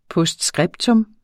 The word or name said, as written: POSTSCRIPTA